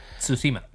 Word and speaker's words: Tsushima: Tsushima.